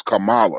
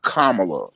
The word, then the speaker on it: Kamala: The issue with Kamala, because I hear a lot of people say her name is Kamala. Her name is pronounced Kamala for those who don't know.